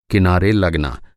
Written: कि

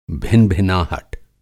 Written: भिनभिनाहट

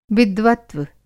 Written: विद्वत्व

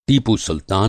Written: टीपू सुल्तान

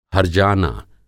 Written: हर्जाना

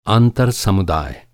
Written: आंतर

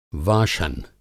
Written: वाशन

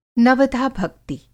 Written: नवधा-भक्ति

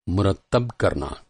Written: मुरत्तब

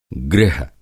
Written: गृह

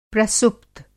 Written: प्रसुप्त

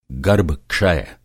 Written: गर्भ-क्षय

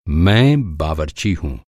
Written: बावरची

